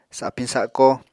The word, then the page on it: sapiñ sako: apatani